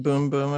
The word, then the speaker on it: Bum Bum: But it's pronounced boom boom, apparently.